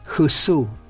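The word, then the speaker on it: Hsü: El segundo de estos hexagramas relacionales del origen es Husu, que se escribe HSU, significa la espera.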